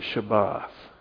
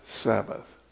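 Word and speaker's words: Shabbat (שבת): In Hebrew, they pronounce it Shabbat. English, we say Sabbath.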